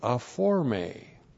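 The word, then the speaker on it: aphorme: A-P-H-O-R-M, long E, aforme.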